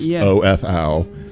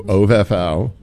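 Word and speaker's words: Ovef Ow: And on their webpage, it says it rhymes with Whoa Jeff Wow. So I'm going to say that it's OFOW. – OVEFOW. – OVEFOW with Do the Worm.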